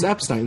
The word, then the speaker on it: Epstein: It's Epstein. It's Brian Epstein.